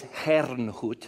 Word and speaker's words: Herrnhut: It's spelled Hernhut, but I think the German is Herrnhut.